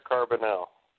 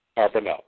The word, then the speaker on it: Carbonell: Carbonell. – Nestor Carbonell. – Carbonell, thank you.